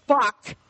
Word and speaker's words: Orbach: It's Jerry Orbach. And yes, you pronounce the H. – Orbach. – B-A-C-H, Jerry Orbach.